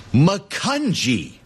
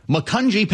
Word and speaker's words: Macungie: Macungie. It's a soft G. Macungie, Pennsylvania.